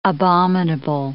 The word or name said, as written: abominable